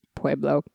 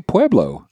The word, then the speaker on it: Pueblo: In and tell us the proper way to pronunciate Pueblo. – Pueblo. – See, Pueblo. – Yeah, but you didn't say that. You said like Pueblo. – Ancestral Pueblo. – Yeah, that's better. That's better.